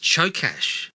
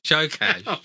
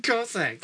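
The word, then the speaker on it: Csokas: The trivia says his last name is pronounced Chokash. – Chokash. – One of those. – Why am I calling him Martin Cossack? – I don't know.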